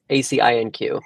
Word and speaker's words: ACINQ: The company that runs it is called Ascin, or like if you pronounce it in French, it's ACINQ.